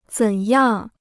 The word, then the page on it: 怎样: zěn yàng